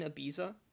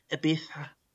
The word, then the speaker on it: Ibiza: I am actually currently in Ibiza. – Well, clearly you're not, or else you would have pronounced it right. Ibiza.